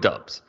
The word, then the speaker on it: Doubs: on me because I I was I was looking through a, a lot of Google records of, of how to pronounce. So we're gonna go with Doubs.